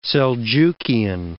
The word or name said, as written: Seljukian